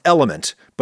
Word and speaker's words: LMNT: Actually, it's pronounced element, but it's spelled LMNT.